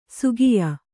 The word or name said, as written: ಶಾಕ್ಯ